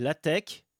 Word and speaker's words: LaTeX: En 1989, il existe déjà à l'époque une multitude de langages sémantiques pour les documents textes, comme TEC et LaTeX. Oui, on prononce pas le X final, c'est un C en fait.